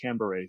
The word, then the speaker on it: Canberra: And then after we went through all the customs and got the rental car, we drove down to Canberra, they call it Canberra, so that's how they pronounced it.